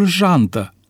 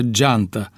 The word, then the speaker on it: jantă: Dar ce spuneți despre substantivul jantă, pe care majoritatea șoferilor îl pronunță geantă, tot așa cum le vine mai ușor să pronunțe marșalier în loc de marșarier?